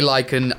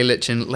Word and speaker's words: lichen: You say lichen, I say lichen, let's call the whole thing moss. And so he says, long story short, I think either pronunciation is valid.